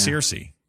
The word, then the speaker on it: Circe: C-I-R-C-S? – C-E? Lannister. – No, that probably is why I said it that way. Am I saying it wrong? C-I-R? – No, you did it right. – Circe. It's Circe.